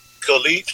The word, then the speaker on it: Caliph: Oh, Khalif, C-A-L-I-P-H, like California.